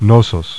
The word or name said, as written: Knossos